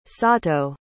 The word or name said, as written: sato